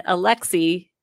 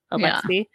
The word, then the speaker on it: Alexei: And Alexei, I think that's how you pronounce it. It's Alex with an E-I on the end. Alexei.